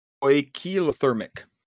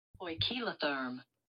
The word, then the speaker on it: poikilotherm: Uh, there's homothermic and poikilothermic. I'm going to double check that I said that properly. Poikilotherm. Poikilotherm. Yeah. I was just like, that's a lot of syllables.